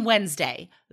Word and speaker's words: Wednesday: So, again, my third grade teacher, she taught us to say, the wedding is on Wednesday. The wedding is on Wednesday. Because Wednesday, it sounds like it's N-D, but it's not. It's spelled W-E-D and then Nesday. So you just remember that D goes first.